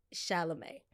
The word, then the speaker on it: Chalamet: They got into actors a little bit with Timothy Chalamanto, or whatever, Chameleon, I don't know. Don't know how to say his last name. Very attractive man. How do you say him? Timothy Chalamet.